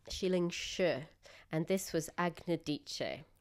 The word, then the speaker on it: Agnodice: Well, if we're going to make it sound a bit Italian, it's like Agnodice. – Dice, that's right. – Or Agnodice.